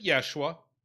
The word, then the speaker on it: Yeshua: They would have pronounced it Yeshua, and which is modernized to Josh.